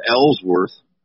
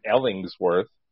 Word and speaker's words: Ellsworth: And mine's Ellsworth. – Not Ellingsworth. – Well, mine's Mole. So it's M-O-U-L-E.